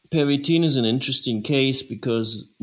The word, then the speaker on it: Pervitin: When the economy in Berlin picked up, weren't a lot of workers using Pervitin, right? Am I saying that correctly? – Pervitin. – Pervitin.